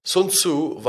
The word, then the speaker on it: Sun Tzu: Sun Tzu war ein chinesischer General und ich weiß nicht, ob ich seinen Namen richtig ausspreche. Ich habe gelesen, man soll seinen Namen Sun Tzu aussprechen, aber Mandarin ist, steht noch auf meiner Liste, das werde ich erst nächstes Jahr lernen.